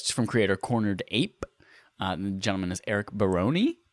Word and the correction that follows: Barone: Baroni rhymes with baloney, or maybe it's Baron with an E on the end. – I would say Barone. – Barone.